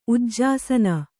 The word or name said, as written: ಉಜ್ಜಾಸನ